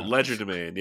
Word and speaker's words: legerdemain: L-E-G-E-R-D-E-M-A-I-N. Ledgerman? – Could you spell it again? – L-E-G-E-R-D-E-M-A-I-N. – AI. – Ledgerman. There's people listening to this right now, like fucking idiots. – Oh, Ledgerman.